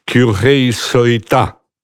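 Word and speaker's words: Kyohei Sorita: Po krótkiej przerwie, po dwóch występach, zaprezentował się Kyuhei Soita. Tak mnie nauczyła wymawiać jego nazwisko Japonka, która koło mnie siedziała.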